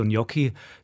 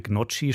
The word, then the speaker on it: Gnocchi: Klößchen aus Kartoffeln und Mehl als so Gnocchi sind vor allem im Norden des Landes beliebt. Die in Deutschland immer wieder zu hörende Aussprache Gnocchi stößt in Italien übrigens eher auf Unverständnis.